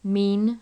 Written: mean

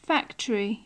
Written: factory

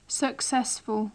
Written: successful

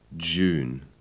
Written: June

